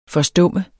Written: forstumme

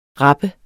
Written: rapper

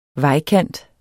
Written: vejkant

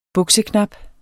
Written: bukseknap

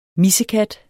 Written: MISSEKATTES

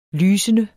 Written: lysende

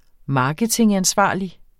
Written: marketingansvarlig

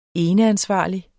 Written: eneansvarlig

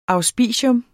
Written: auspicium

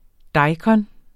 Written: daikon

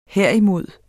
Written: herimod

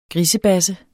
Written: grisebasse

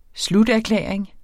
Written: sluterklæring

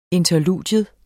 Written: interludium

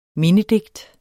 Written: mindedigt

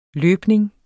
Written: løbning

